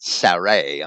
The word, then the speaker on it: Sarai: And Abram took Sarai, his wife, and I'm pronouncing that Sarai. I'm going to try to stay consistent with that. It's Sarai in English.